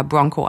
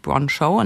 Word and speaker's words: Broncho: spricht sich das wohl Broncho? Es schreibt sich wie Poncho, aber mit BR vorne. Oder spricht es sich doch eher Bronco? Also ich habe gefragt, es spricht sich Broncho.